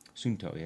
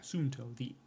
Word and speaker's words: Suunto: Is it Suunto? Is that how it's pronounced? – Suunto, yeah. – Suunto.